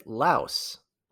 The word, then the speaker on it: Laos: So with Laos, let's start with the name. You may hear it pronounced Lao, which is what people in the country call it, but in English, there's an S at the end.